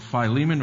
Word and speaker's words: Philemon: I know that some people pronounce it differently. My New Testament prof kind of hammered it in my head that that's how it's pronounced, Philemon.